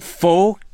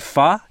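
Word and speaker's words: phở: They were a little bit bloody, and uh, we we I called that pho p h o, but I learned later that it's it's actually fa in Vietnamese. I don't know why it's spelled with an o.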